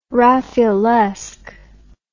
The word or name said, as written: raffaelesque